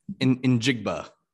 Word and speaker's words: Njigba: is that how you say his name? – Njigba.